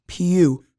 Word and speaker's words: PewDiePie: It's not PewDiePie. It's PewDiePie. Pew. Pew, pew, pew. It's P-E-W.